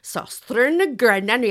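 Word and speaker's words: Søstrene Grene: Subby Liam said her friend, who knows about these things, says it is Sostrin and Grenna. You have to say it like that. And you kind of have to put your shoulders up to your ears.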